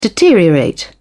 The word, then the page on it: deteriorate: British English